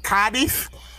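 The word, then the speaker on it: Cádiz: Yeah, so I think the proper pronunciation is Cadiz. There's an accent on the A and the stress you got to get just right. So you got to like really stretch your jaw, get it all out there.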